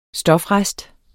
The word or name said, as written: stofrest